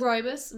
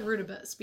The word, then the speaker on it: rooibos: I used to call it rutabus before I ever heard how it was like pronounced. So yeah, mm-hmm. everybody says it a little different. – Yeah. But it's rooibos.